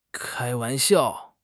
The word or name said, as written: kai1 wan2 xiao4